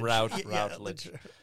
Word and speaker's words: Routledge: I'm talking with Routledge or is it Routledge? I never know how to say it. – Yeah, I guess how pretentious. You're at Harvard now, so I think it's Routledge.